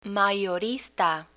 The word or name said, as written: mayorista